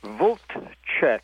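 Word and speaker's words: Vltchek: Voltchek,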